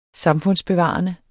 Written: samfundsbevarende

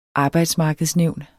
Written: arbejdsmarkedsnævn

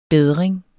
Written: BEDRINGEN

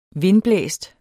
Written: vindblæst

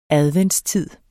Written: adventstid

